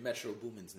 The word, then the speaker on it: Metro Boomin: I just wanted to make sure I was saying Metro Boomin's name correctly. – Yeah, you're saying it correctly.